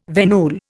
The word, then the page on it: venule: Indian